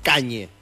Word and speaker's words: Kanye: Aparte, Kanye se pronuncia como se escribe, boludo. Kanye.